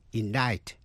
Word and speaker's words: indict: Và cuối cùng là C, câm, tức là không phát âm. Trong những chữ như indict, I-N-D-I-C-T, indict, indict, truy tố.